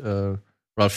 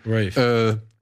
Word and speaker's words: Ralph: Das ist Ralph, ne? – Mhm. Ja. Rafe. – Rafe. Rafe? Ralph, oder? – Rafe. – Rafe Fiennes? – Wird er so ausgesprochen? – Mhm. Okay. Ganz wirklich? – Aber er wird doch Ralph geschrieben, oder? – Ich weiß.